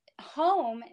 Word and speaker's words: Hoam: Okay, so home, even though it sounds like H-O-M-E, it is H-O-A-M. So it sounds like home, but it's spelled a little bit different.